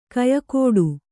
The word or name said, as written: ಗೊಯ್‍ಗುಟ್ಟು